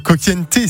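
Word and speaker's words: Cocciante: Le coup de soleil de Richard Coquienté, c'est comme ça que ça se prononce normalement.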